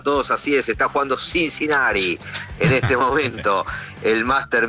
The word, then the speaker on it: Cincinnati: Creo que es a ver cómo lo digo, Cincinnati. – Sí, no es el Cincinnati, esa T no es como la pronunciamos nosotros en castellano. – Mi profesor de inglés, Cincinnati.